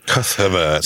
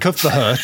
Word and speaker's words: Cuthbert: I don't know why I said that so weirdly, because one of the versions of it puts an extra H in before the T and I didn't know how to pronounce Cuthbert. – Cuthbert. – St. Cuthbert.